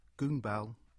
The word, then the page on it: Goonbell: English